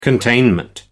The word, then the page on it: containment: American English